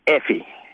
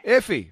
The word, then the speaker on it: Aoife: Effie. – Effie, right, it's spelled A-O-I-F-E, and it's Effie, right, I'll take your word for that.